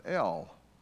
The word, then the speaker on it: El: God in our English translations is diluted from the meaning of the original Hebrew, because this word in Hebrew is a two-letter word, E-L, simply pronounced L, and it means God the Most High and All-Powerful.